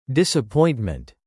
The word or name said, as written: disappointment